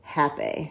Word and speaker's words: rapé: Oh, here, rapé is the legal sacred shamanic medicine that has been used by healers of the Amazon basin for thousands of years. It's pronounced happy. – Yeah, happy.